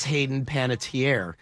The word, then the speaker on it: Hayden Panettiere: Hayden Panettiere.